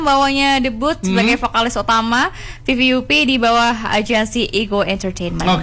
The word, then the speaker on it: VVUP: Selain Carmen teman Pro 2 dan juga Kim dari VVUP. Benar enggak sih bacanya? – Benar banget. Tadi aku bacanya kayak whoop begitu.